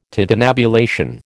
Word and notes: tintinnabulation: American English

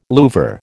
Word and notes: louvre: American English

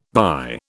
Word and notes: thy: British English

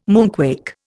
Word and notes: moonquake: Indian

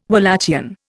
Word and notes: Walachian: Indian

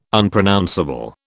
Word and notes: Unpronounceable: British English

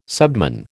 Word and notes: subman: American English

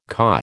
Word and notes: caw: American English

